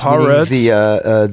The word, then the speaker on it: Haaretz: Haaretz. – Haaretz.